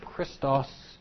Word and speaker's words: Christos: You know that our Savior's name, Christ, or Messiah, in Greek is pronounced Christos. If you replace the I with an E, so that instead of Christos, you say Krestos, that is the word kind.